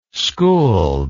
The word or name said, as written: skuld